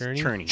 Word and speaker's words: Czerny: I think it's pronounced Czerny.